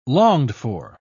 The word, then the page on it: longed-for: British English